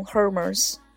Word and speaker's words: Hermès: 比如说，你是否曾经责备过爱马仕这个单词明明是英语的 H 开头，却要被读成 Hermes， H 变成了一个摆设字母，根本就不需要发音，好吧。